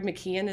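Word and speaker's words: McKeown: I think at this stage of the game, I think his name is Greg McKeon, is how you say his last name.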